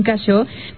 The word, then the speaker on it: Cacheux: Yasmín Cacho. Recuerden, se escribe Cacheux con X al final.